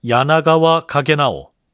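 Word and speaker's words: 야나가와 가게나오 (柳川景直): 이때 대마도주가 보낸 평경직은 일본 사서에는 성씨가 유천으로 돼 있어서 유천경직, 일본어 발음으로는 야나가와 가게나오 뭐 이렇게 해야 옳겠지만요.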